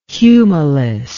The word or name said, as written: humilis